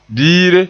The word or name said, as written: biire